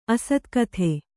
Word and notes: ಅಸ್ತಗತಿ: Kannada

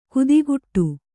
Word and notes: ಕುದಿಗುಟ್ಟು: Kannada

